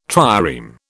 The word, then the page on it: trireme: British English